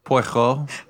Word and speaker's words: Poirot: We don't need Hercule Poirot, or however you say his name. I don't know. – Poirot.